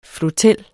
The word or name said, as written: flotel